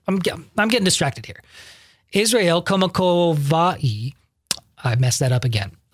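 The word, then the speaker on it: Kamakavivole (Kamakawiwoʻole): We are looking at how to pronounce the name of native Hawaiian singer, lyricist, and musician, Israel Kamakavivole. – Israel Kamakavivole. – Israel Kamakavivole.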